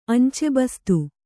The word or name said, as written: ಅಂಚೆಬಸ್ತು